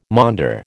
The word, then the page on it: maunder: American English